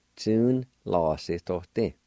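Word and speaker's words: tsunalasidodi: Number five, bylaws, Tu-na-la-si-to-ti, Tu-na-la-si-to-ti, Tu-na-la-si-to-ti. The A with the N, sometimes that will be silent, pronounced like this. Tune la si to ti. Sometimes you might hear it that way.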